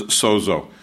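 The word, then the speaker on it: sozo (σώζω): And the first one, the most common one I'm going to cover is sozo, and it's spelled in English letters just the way it sounds, S-O-Z-O, sozo.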